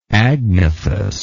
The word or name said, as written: agnathous